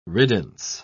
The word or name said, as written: riddance